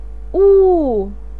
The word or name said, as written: ou4